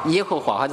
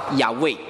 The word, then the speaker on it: 耶和华: 那么耶和华的这个词，这个名字的希伯来原文的发音其实应该不是叫耶和华，或者是叫不是叫耶和华，其实它原文的发音应该是叫亚卫。